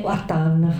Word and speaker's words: Artan: Artan. Artan, forse è meglio dire, no? L'accento giusto.